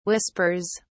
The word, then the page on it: whispers: British English